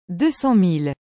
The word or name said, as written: 200000